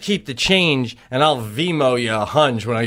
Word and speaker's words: Venmo: That's why Lou and I were dying with Venmo.